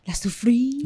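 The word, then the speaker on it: La Soufrière: La Soufriere.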